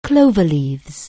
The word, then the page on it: cloverleaf: anglais